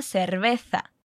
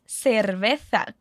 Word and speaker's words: cerveza: Percebeu que ela diz cerveza com essa pronúncia no tha? Como a Thaís já explicou para você em episódios anteriores, na Espanha essa é uma pronúncia muito comum, mas estaria completamente correto dizer cerveza. Completando a frase, a gente tem a nossa palavrinha mágica, por favor, que a gente já viu em episódios anteriores.